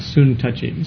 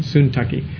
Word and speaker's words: Syntyche: Actually, Syntyche can be pronounced a little bit more like soon touchy, soon tucky.